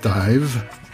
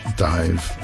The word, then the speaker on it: Daimh: It's pronounced Dive, D-A-I-M-H, Dive, in Scots Gaelic.